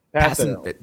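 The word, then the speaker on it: Kpassagnon: Starts with KP. – Yeah. – Do you want to help me out here? – Passon. – Passon. It's a case silent, I assume.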